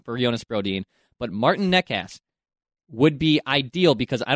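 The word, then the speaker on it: Nečas: Nekas is how you pronounce this name.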